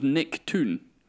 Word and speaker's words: Toon: Did you say his name was Nick Toon? – Yes, like Nickelodeon.